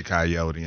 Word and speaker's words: Coyote: Coyote. – And so, Coyote, as it's pronounced around here.